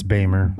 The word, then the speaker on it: Beymer: It's Beimer. – Beimer. – B-E-Y-M-E-R. – I know all these names. I know them all. – And how are you saying it? – Beimer?